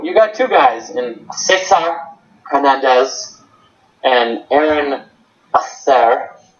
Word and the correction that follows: Altherr: It's pronounced Altherr for one, but I'm glad you pointed out the German.